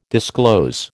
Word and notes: disclose: American English

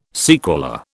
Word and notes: sequela: British English

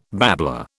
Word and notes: babbler: British English

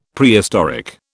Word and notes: Prehistoric: British English

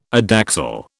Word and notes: adaxial: British English

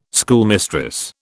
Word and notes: schoolmistress: British English

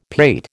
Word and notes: pate: American English

